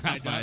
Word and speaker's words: Taza: T-A-Z-A. Yo no puedo pronunciar Taza.